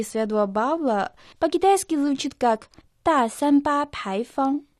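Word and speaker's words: та сан ба пай фан (大三巴牌坊): «Та» произносится в четвертом тоне. В переводе на русский это «большой». «Сан ба» – это не очень точная транскрипция имени Святой Павел. «Пай фан» произносится отдельно во втором и первом тоне.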